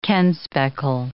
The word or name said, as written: kenspeckle